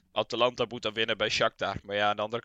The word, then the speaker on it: Shakhtar: Ja, dat is, dan moet dus op de laatste dag moet Atalanta winnen bij Shakhtar.